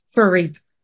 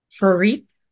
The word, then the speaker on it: Froriep: Froriep. – Say it again. – Froriep.